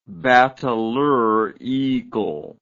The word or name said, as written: bateleur eagle